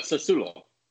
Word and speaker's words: Sassuolo: Sassuolo.